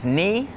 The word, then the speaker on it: knee: Some examples with a silent K are the words knee, night, and knife.